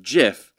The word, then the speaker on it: GIF: But he was just out there saying, like, it should be pronounced GIF, like the peanut butter, rather than a hard G.